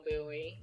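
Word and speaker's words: Bouie: Buoy, and I'll probably be available.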